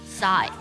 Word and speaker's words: sigh: 下个表情叹气 sigh，叹气呢它的英文呢就是 sigh， sigh，在这里呢 G 是不发音，I 发本身的字母音，表示的是疲惫、失望或是不开心的意思。